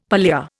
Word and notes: pallia: Indian

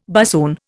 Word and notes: bassoon: Indian

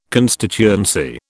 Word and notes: constituency: British English

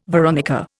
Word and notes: veronica: Indian

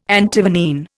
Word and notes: Antivenene: Indian

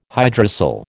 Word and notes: hydrocele: American English

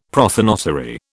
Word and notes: prothonotary: British English

